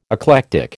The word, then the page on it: eclectic: American English